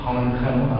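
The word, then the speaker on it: Groningen: Ano, ten pán, který za to může, se jmenuje Heike Kamerlingh-Onnes, takhle se to dokonce vyslovuje, holandský fyzik, nalezen Groningenu. Honchenu, ano, bych to správně vyslovil, pracoval v Leidenu, zemřel v Leidenu.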